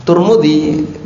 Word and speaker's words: Tirmidzi: Tirmidzi bisa dibaca dengan Tirmidzi, dan itu yang lebih masyhur. Bisa dibaca dengan Tirmidzi, sama-sama di dhamma. Bisa juga dibaca dengan Tirmidzi. Bisa juga dibaca dengan Tirmidzi. Jadi ada banyak cara baca. Yang paling masyhur Tirmidzi, pakai kasrah. Yang juga cukup masyhur Tirmidzi. Sedangkan yang Tirmidzi kurang begitu masyhur kecuali di Indonesia.